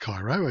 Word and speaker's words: Cairo: Well, that song probably does, called Going Down to Cairo, C-A-I-R-O, which is in southern Illinois. Not pronounced Cairo, as you heard on that track. It's pronounced Cairo.